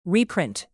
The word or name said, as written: reprint